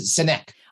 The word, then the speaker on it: Sinek: I believe it pronounces it Sinek. – Oh, Sinek. Okay. Well, I think he pronounces it Sinek, I think.